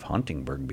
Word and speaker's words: Huntingburg: I never heard of Huntingburg before.